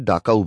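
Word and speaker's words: Dachau: দীপাঞ্জন শুধু তার মধ্যে ইংরেজিতে লেখাই পড়তে পারল, ডাকাও concentration camp Memorial site. Professor জুয়ান বললেন, DACHU, এই শব্দটার মধ্যে এইচ উজ্জ থাকে, জার্মান ভাষায় এর শব্দটা উচ্চারণ করে ডাকাও বলে।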